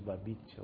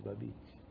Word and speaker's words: Babette: A Festa de Babite. Se escreve Babette.